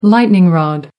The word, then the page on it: lightning rod: British English